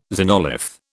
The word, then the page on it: xenolith: British English